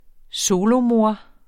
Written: solomor

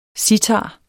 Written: sitar

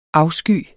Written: afsky

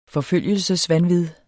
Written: forfølgelsesvanvid

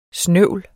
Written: snøvl

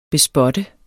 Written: bespotte

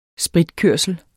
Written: spritkørsel

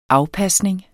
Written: afpasning

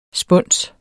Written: spunse

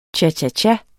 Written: cha-cha-cha

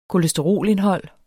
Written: kolesterolindhold

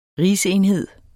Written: rigsenhed

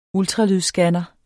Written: ultralydsscanner

